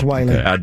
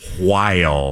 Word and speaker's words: Wile: Damn it, I was hoping it was Wiley. W-I-L-E looks a little bit like Wiley, but it's pronounced Matt Weil.